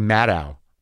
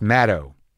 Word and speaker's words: Maddow: I think I've been saying her name wrong forever. I say Maddow. Rachel Maddow, but it's Maddow. Like shadow.